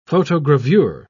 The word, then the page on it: photogravure: British English